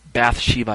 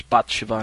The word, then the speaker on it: Bathsheba: Um, Bathsheba, I think is how it's pronounced in English, Bathsheba in Hebrew.